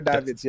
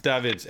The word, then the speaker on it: Davids: Is it Edgar Davids or Edgar Davids? – Yeah, Edgar Davids, yeah. – Davids.